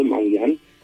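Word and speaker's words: Mangyan: Da, așa se pronunță Mangian.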